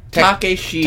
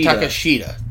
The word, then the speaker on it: Takeshita: Hangman Page versus Konosuke Takeshita. I believe I'm pronouncing that right. From Dynamite a couple weeks ago. It is so awesome. Takeshita, or... – No, I think it's Takeshita. – Takeshita. That's it. Yeah, Takeshita is so good.